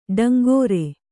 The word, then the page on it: ಟೇಂಕಾರ: Kannada